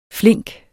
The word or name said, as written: flink